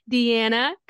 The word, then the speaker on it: Diana: but it's really Deanna. My parents kind of messed it up a little bit because we're Italian. So that's the Italian pronunciation.